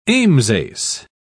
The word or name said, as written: ambsace